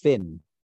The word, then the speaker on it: Fionn: And Fionn Whitehead. – I think it's pronounced Finn.